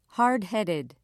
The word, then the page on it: hardheaded: British English